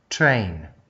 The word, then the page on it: train: Australian English